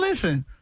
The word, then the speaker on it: listen: Listen is another one where the English words has a silent letter. – Silent and listen? – T. Listen. You ain't no T in listen.